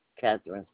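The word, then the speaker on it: Katherine: Catherine, spelled with a K, Hunter.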